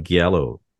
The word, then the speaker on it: Giallo: You mean Yalo, like you're pronouncing it. I would say get, like, what do you mean Yalo? You're not talking about Giallo.